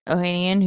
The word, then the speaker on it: Ohanian: Ohanian, who's married to none other than, what's her name? – Serena Williams.